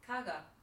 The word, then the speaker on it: caggey: So the way it's spelt is just... blows your mind because when you say it, it's completely different. So it's about C-A-G-G-E-Y, mm-hmm. – which to me says K-G-E. – Kagi. – Kagi. – Isn't she made in Chelsea? Yeah. how do you think it said? – I think it's pronounced Kaga.